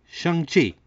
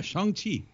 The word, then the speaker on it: Shang-Chi: Shang-Chi. – Hey! – All right. – And the crowd goes wild. Yeah, Shang-Chi.